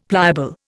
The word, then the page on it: pliable: Indian